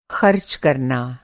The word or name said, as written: कर